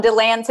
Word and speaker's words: DeLand: How do you say it? DeLand or DeLand? – DeLand. – DeLand. Yeah. So if somebody comes to town and they say DeLand, you know they're from out of town.